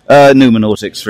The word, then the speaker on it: Noumenautics: And you're the author of Nomenautics. – Correct. – Is that how you pronounce it? – Noumenautics, really. But, you know, there's no correct pronunciation as I made it up.